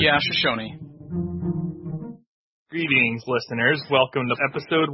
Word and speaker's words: Shoshone: For me, I'm looking forward to playing the Shoshone. Is it Shoshone? They need like a pronunciation guide for us. So yeah, I believe it is.